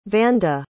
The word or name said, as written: vanda